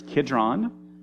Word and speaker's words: Kidron: It's Kidron and Malchus.